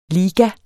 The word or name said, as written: ligas